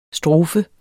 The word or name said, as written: STROFERNE